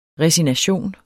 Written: resignation

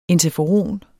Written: interferon

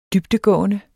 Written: dybdegående

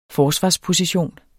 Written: forsvarsposition

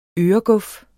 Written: øreguf

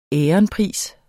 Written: veronica

